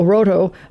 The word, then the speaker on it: Rohto: So, they pronounce it Roto? – I guess, R-O-H-T-O, Roto, yeah. I guess.